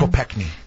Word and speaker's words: Kopechne: Kopechne.